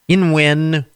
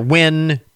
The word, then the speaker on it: Nguyen: The Vietnamese surname, Nguyen, or Nguyen, N-G-U-Y-E-N, Nguyen, or Nguyen, Nguyen. There are a number of different pronunciations that are acceptable for that, depending on the family. It's a surname in common in Vietnam, so different families pronounce it different ways. Gnocchi. Gnocchi. I'm sorry. See, I can't pronounce it. Gnocchi. G-N-O-C-C-H-I is sixth, while the technology controversy around the contentious word GIF is in seventh place.